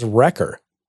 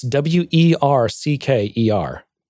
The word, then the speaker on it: Wercker: I always read it as Wrecker. It's W E R C K E R. It's Wercker, yeah. but I always see Wrecker. I always want to say Wrecker.